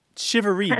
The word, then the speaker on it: charivari: I've seen it C H A R I V A R I, mm-hmm. but it's pronounced like S H I V A R E E.